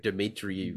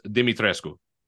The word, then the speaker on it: Dimitrescu: Lady Dimitri. – They fucked her name up. It's supposed to be pronounced Dimitrescu.